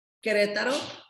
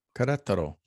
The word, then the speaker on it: Querétaro: Q-U-E-R-E-T-A-R-O. – Okay. – Querétaro. That's the capital Querétaro. City and the state. – Okay, Querétaro. All right. – You got it. You said it right.